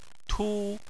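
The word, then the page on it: tu: tone 1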